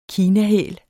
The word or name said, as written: kinahæl